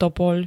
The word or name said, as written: topoľ